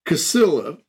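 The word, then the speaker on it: Cäzilia: it's pronounced Casille, right? I'm pretty sure it is. – Maybe. Casilla. – Casilla. It's Casilla is the way it's pronounced.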